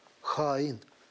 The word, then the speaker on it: Хаин: Есть одна особенность в тюркских языках, в частности, в азербайджанском, все ударения только на последний слог. Вы услышали? Хаин. Правильно? Правильно.